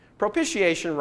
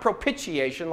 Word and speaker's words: propitiation: Propitiation rhymes with initiation. I just want us to get it right, okay? It's not propitiation like I used to pronounce it myself.